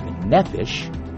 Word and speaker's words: nephesh: This Hebrew word here, the word soul, comes from a Hebrew word called or pronounced nephesh, which simply means air-breathing creature.